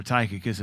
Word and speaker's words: Tychicus: And Paul had his Timothys and his Tychicus, or Tychicus as we pronounce it, and his Tituses and his Epaphrodites.